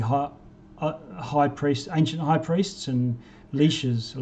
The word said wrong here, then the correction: lich: Lich is the uh, is proper pronunciation.